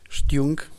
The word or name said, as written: xtiung